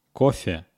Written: kofe

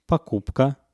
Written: pokupka

